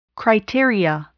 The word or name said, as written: criteria